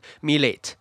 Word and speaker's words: milet: 呃，非美国、非英国的名字念起来，毕竟 T 不发音，所以有些人可能会不知道，会变变呃会念成 milet，然后有一个 T 的发音在后面，但其实它不发音这样。